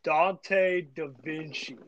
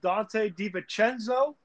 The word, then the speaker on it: DiVincenzo: You did not just say Dante DaVinci. – I call him Dante DaVinci, bro. I call him Dante DaVinci. – I love it. And for the viewers out there, it is pronounced Dante DiVincenzo.